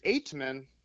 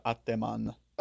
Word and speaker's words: Eightman: And the first one they did was Marcel Aitman, which I figured Aitman would be, was pretty self-explanatory. – I don't know. – Because First time I saw it, I said Ateman. – oh, did you? Maybe I heard it first. Maybe that was my problem. But it's spelled E-I-G-H, or it's pronounced E-I-G-H-T-M-A-N.